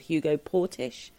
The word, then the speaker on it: Hugo Portisch: Now, you may correct my pronunciation on this. I believe he was called Hugo Portisch.